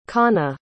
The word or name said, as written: Kanha